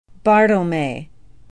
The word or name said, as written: bartelme